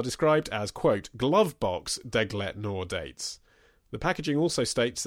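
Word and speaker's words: Deglet Nour: These dates are, now I don't even know how to pronounce it, Degle Noir. Is that it? Degle Noir? – It could be. – Degle Noir? I don't know. My French isn't great. I've got a D. – It's spelled Deglit. – Yeah, Deglit Noir.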